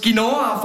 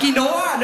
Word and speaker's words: Quinoa: Ich sehe eine Mutter, die mit ihrem Kind schimpft und aus Quinoa falsch gesagt hat. Es heisst Quinoa, nicht Quinoa.